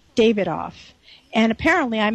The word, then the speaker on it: Davidoff: So sure enough, I go the second day, and I pronounce it the British way, and I get right in. So the British way, in case you're ever in London, is Davidoff.